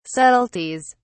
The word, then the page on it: subtleties: British English